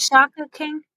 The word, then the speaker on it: Shaka King: Is it Shaka King? – I looked it up. It is. And apparently it's his first feature. – I'm pronouncing it right.